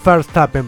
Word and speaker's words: Verstappen: Y también sobre algo que había dicho tanto Max Verstappen como Verstappen, para que se ponga bien, para que lo pronuncie de la manera correcta, porque Dani se enoja.